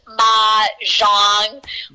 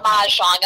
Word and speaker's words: mahjong: And I will just tell you, it is pronounced Mahjong, like ma, like M-A, like, you know what I mean? Okay. Mahjong.